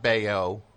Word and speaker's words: Baio: I have the same thing with Scott Baio. How do you say that name? I always get it confused. – Well, you got that right. Scott Baio and Born on the Bayou.